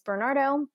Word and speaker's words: Bernardo: My first name is spelled J-O-A-N-E-L, and my last name is Bernardo, B-E-R-N-A-R-D-O.